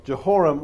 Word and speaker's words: Jehoram: Now, Jehoram, I said it wrong a minute ago, Jehoram was a king of Judah.